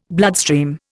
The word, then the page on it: bloodstream: Indian